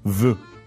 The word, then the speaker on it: vœu: Vœu.